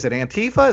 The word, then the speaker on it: Antifa: Antifa? Is that how you pronounce it? – Yeah. – I think so.